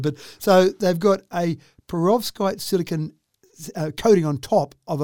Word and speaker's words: perovskite: So what they've done is they've used, and help me if I've got this wrong, perovskite. How's that sound for pronunciation? – Sounds good to me. I've never used that word before. – Okay, good. – So we're going to go with perovskite.